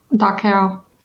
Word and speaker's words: Dachau: I believe it's Dachau? Dachau? – Dachau. – Dachau. Okay. I'm unfamiliar with that one in particular, obviously, because otherwise I would have pronounced it correctly.